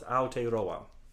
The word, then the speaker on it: Aotearoa: Well, with my own American accent and all due respect to Maori people, it's Aotearoa. – Nice. – Aotearoa.